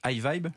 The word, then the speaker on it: Hyvibe: Vous êtes l'un des cofondateurs de iVibe, je le prononce bien Oui, Parfait.